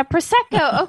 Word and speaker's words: Prosecco: Prosecco? – And at first, yeah, Prosecco.